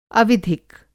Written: अविधिक

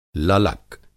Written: ललक

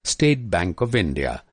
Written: स्टेट

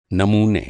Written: नमूना

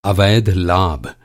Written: अवैध

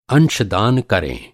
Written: अंशदान

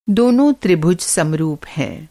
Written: समरूप

